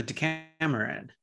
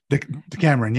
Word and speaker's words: Decameron: But you know, one thing I wanted to say is, have you heard of the, I'm probably pronouncing it incorrectly, but the Decameron or the Decameron? – The Decameron. – Decameron, yes.